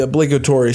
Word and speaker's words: obligatory: This is also the obligatory statement. I don't know if I said that right. – Yeah, obligatory? – Yeah, you're good. You're good. – Okay. – Nailed it.